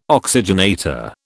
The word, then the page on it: oxygenator: British English